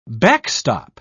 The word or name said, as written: backstop